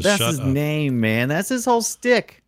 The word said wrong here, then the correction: shtick: His stick? – Shtick. – There you go. – His whole shtick. What do you mean to pronounce every syllable and vowel and every word? I don't have time for that. – I think stick can lead people down the wrong path.